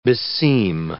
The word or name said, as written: beseem